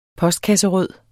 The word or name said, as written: POSTKASSE